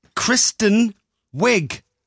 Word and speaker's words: Kristen Wiig: It's Kristen Wiig.